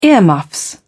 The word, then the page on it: earmuffs: British English